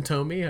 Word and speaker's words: Tomy: And I, said Tommy earlier, so you said Tommy, but he was a great ranger. So we'll no, that's fine, it's fine. I might be pronouncing it weird too, but yeah, Takara Tomy, I think. Let's go with Tomy.